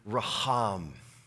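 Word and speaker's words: raham (רחם): It's the Hebrew word, compassion is the Hebrew word, raham. You have to stir up a little phlegm to say it right.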